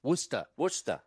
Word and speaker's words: Worcester: Is that how you say it? Come on. – How do you say it? – Come on. – Worcester. – And it's Worcester for sure.